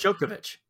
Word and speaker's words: Djokovic: Novak Djokovic? No, that's wrong. – Yeah, that's right. – Did I get that right? – It's Djokovic. Yep.